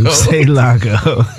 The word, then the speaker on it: Loucielago: It rhymes with who made tacos. – Who made tacos? Loucielago. – Loucielago.